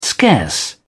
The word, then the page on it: scarce: British English